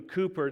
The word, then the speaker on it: Cowper: It's spelled C-O-W-P-E-R, but it's Cooper, not Cowper.